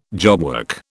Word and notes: jobwork: British English

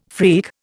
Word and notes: freak: Indian

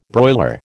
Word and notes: broiler: American English

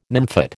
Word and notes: nymphet: American English

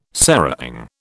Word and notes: serang: British English

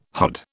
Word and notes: hod: British English

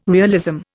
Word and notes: realism: Indian

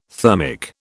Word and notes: thermic: British English

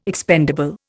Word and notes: expendable: Indian

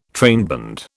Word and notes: trainband: British English

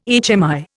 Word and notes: HMI: Indian

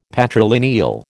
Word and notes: patrilineal: American English

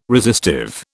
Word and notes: resistive: British English

